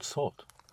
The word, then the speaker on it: sorte: Quelle sorte? – Right.